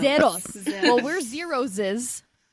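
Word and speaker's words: ZEROSE: Because I think the way to pronounce it is like zeros, like roses, like the flower roses.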